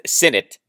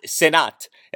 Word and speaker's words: Sinnott: The last name is pronounced Sinnott, not Sinnott, as you may have heard, Sinnott.